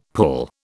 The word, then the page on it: pull: British English